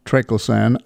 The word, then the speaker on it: triclosan: Triclosan is the way we like to pronounce it, right. But there are various other possibilities.